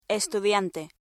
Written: estudiante

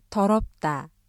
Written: 더럽다

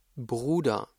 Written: Bruder

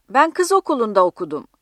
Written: kız

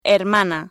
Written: hermana

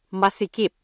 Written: masikip

